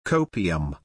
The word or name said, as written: copium